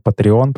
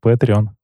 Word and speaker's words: Patreon: Ну, в России говорят Patreon почему-то, но правильно читается Patreon, окей.